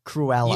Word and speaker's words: Cruella: How do you say it? Cruella. – You had it right, and then you doubted yourself.